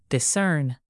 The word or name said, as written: discern